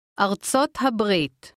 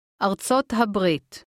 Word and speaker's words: ארצות הברית: ארצות הברית. הברית. ארצות. ארצות הברית.